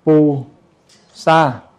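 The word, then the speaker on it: Pu Sa (菩萨): Bồ Tát á, thì mình đọc là Pu Sa.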